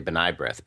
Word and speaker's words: B'nai B'rith: I'd also suggest he learn how to pronounce it and say B'nai B'rith, but that's just me.